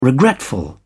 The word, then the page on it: regretful: British English